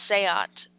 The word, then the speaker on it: SEAT: A black Sikh car, or Seat, S-E-A-T, it's spelled like seat,